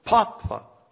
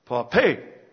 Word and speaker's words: papa: If he wheels the baby carriage and carries bundles meekly, they call him, emphasizing the first syllable, papa. If he belongs to a literary circle and writes cultural papers, they called him with the accent on the last part of the syllable, papé.